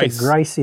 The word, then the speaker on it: Grice: So I pronounce Grice.